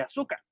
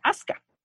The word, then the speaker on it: Asuka: O más bien Asuka, ¿no? La pronunciación correcta. Porque aparte a mí me, a mí me acostumbraron en los 90 con Evangelion que era Asuka y ya después me salen que es Asuka.